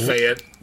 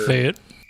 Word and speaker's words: Fayette: It's pronounced Fayette, but sure. – Fayette. – It's like Fayetteville, North Carolina.